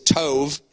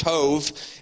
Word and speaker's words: tov: This Hebrew word is tov. It's actually T-O-B, but it's pronounced tov.